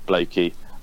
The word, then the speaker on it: Blakie: Um, and then if you feel like giving me a follow as well, at Jonathan Blakie.